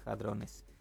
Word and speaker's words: hadrones: El apagado de este gran colisionador, se escribe hadrones con H, vamos a decirle hadrones, volvió a generar dudas y teorías sobre posibles agujeros negros.